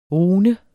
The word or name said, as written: rune